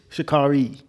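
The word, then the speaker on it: Sha'Carri: Shikari Richardson? – Shikari? – That's what it is. No, Shikari. – Shikari. Oops. – I think that's how you pronounce it.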